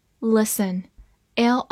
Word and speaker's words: listen: listen，l i s t e n，listen，l i 发 l，s 发 s，t 不发音，e n 发 n，listen。